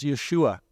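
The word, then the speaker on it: Yeshua (ישוע): However, the original Hebrew pronunciation of his name is Yeshua, which is a form of the name Joshua.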